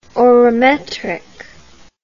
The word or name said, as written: orometric